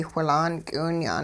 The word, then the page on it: whilaan k'iwinya'n: hupa